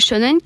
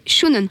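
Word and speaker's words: shōnen: C'est un type de manga, par exemple, le mot shonen qui signifie adolescent. Au japonais, c'est parfois prononcé shounen.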